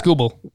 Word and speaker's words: Skubal: Skubal? I don't know how to pronounce that. – Skubal. – Sorry.